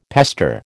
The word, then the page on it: pester: American English